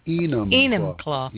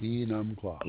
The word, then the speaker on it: Enumclaw: How do you pronounce that? Enumclaw? – Enumclaw. – Enumclaw. – Enumclaw. – Okay.